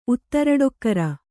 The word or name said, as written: ಉತ್ತರಡೊಕ್ಕರ